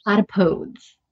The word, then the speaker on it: platypodes: How do you say the plural? – It's, oh my goodness, it's platypodes.